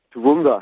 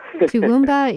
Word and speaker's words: Toowoomba: Toowoomba. – Toowoomba in Queensland, Australia. – Yeah. – And you C-O-O-W-O-O-W-O-O-N-B-A.